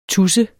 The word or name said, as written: Tudserne